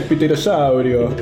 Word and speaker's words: pterosaurio: Ah, el pterosaurio. – No, es como argentino. – Argentino, aquí no sé cómo se dice. – Pterosaurio. – Pterosaurio. Pterosaurio. Sí, es pterosaurio, sí.